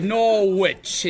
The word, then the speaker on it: Norwich: Norwich. It's not a fucking witch there, you know.